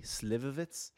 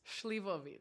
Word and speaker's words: Slivovitz: Slivovitz? – Schlivovitz.